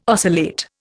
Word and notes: oscillate: Indian

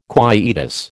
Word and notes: quietus: American English

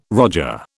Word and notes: roger: British English

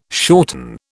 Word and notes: shorten: British English

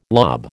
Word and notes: lob: American English

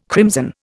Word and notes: crimson: Indian

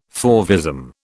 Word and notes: fauvism: British English

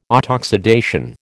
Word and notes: autoxidation: American English

